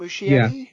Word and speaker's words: Muschietti: Okay, so this Andy Muschietti, is that how you pronounce it? Muschietti? – Yeah.